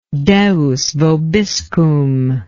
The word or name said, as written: Deus vobiscum